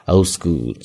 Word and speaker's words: old school: 那注意它的发音，old school。那中间的字母 d 往往读快的时候呢，读得很轻，几乎是没有发出来。 old school，所以呢，有的时候你听到是这样的一个发音。